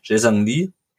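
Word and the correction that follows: Jae-sung Lee: ähm, Chesung Chesung Lee kam dann für Ingwertsen.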